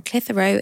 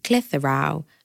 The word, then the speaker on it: Clitheroe: Love, Becky from Clitheroe in Lancashire. Pronounced Clitheroe, not Clit-Hero, as people like to say.